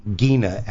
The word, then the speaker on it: Gina: Alexander and Gina, actually, is how she pronounces her name, Gina Hecht.